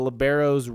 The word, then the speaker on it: libero: but can you kind of explain the libero's role on the volleyball squad? – Okay, yes, I'd be glad to. So all around the world, her position is called the libero. That is the correct pronunciation of that.